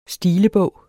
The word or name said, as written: stilebog